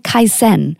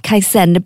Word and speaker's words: Kaizen: And then apply a Japanese concept It's called Kaizen. Sorry for butchering the beautiful Japanese language. It's spelled K-I-Z-E-N if you want to look it up. Kaizen.